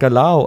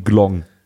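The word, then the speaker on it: galão: Das heißt doch Galau. Und Miguel hat mir jetzt bestätigt, nein, in Portugal spricht man es tatsächlich so aus, dass es sich anhört wie Glong.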